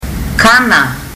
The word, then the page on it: kana: fiji